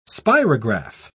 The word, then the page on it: spirograph: British English